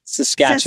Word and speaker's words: Saskatchewan: Sasquamish? Sasquamish? – Oh, so close. Saskatchewan. – Saskatchewan. – Yes, okay. Spelled like Saskatchewan, but we say Saskatchewan.